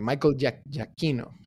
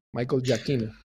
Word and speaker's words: Michael Giacchino: Exactamente, Michael Giacchino, para pronunciarlo bien en, italiano. Este, A ver, eh, producción nos corrige. – Sí, Michael Giacchino, ¿no? Producción. Está bien, Michael Giacchino.